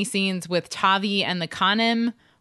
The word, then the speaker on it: Canim: It sounds to me like Khanim, but it's spelled C-A-N-I-M. So yeah, Khanim.